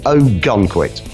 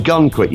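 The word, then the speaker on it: Oh! Gunquit: and this is a band called Ogunquit. Mm. Ogunquit, you might remember, Tony, is spelt unusually. It's O-H, exclamation mark, Gunquit, G-U-N-Q-U-I-T, one word.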